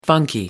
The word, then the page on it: funky: American English